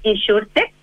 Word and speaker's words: Insurtech: Yo soy la directora ejecutiva de la Asociación Gremial Insurtech Chile, lo pronunciaste muy bien.